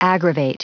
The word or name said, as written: aggravate